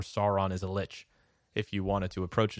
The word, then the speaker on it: Lich: There's the Lich, which I've also heard pronounced leech and lick and all sorts of other things, but I think Lich is the agreed upon pronunciation. – Yeah, I think it's Lich.